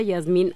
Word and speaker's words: Jazmín: Se escribe Jazmín y se pronuncia Yasmín.